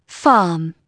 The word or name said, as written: farm